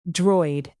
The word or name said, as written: droid